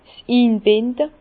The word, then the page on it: 's Inbinde: Bas-Rhin; Herrlisheim